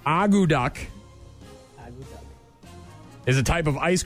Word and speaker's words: akutaq: And it's spelled A-K-U-T-A-Q. Agu duck.